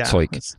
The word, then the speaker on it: Zeug: Zeug.